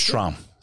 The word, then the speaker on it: Strom: Or is it Strom? – Strom, like strumming a banjo. That's what I tell people.